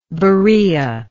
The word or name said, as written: Berea